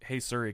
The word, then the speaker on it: Siri: But so after that, I just had to be like, hey, Siri, call whoever.